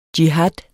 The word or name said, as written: jihad